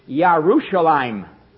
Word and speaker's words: Yerushalayim: And it's not Jerusalem. It's Yerushalayim.